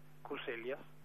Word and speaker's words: Concellas: Pero Pablo Concellas, para pronunciar bien su apellido, Concellas, ya ejerció como responsable legal y técnico en la ciudad de Buenos Aires durante la jefatura de Macri en la ciudad.